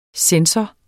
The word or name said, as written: censor